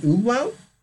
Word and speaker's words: umlaut: what I believe is known as an umlaut over the U, so I don't know how that's not pronounced. – You say umlaut? – Umlaut. Umlaut. Okay. Umlaut. – That's right.